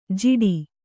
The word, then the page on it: ಜಿಡಿ: jiḍi